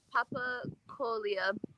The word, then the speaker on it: Papakolea: With Hawaiian, you can kind of look at the word and just break it up as syllables most of the time. Not all the time, but most of the time. And so, papakolea.